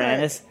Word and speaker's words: anise: Star anise? – No, she said it would be star anus. – Well, he's just mispronouncing anise.